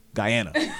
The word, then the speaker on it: Guyana: This is not GH Ghana. This is GUY Guyana.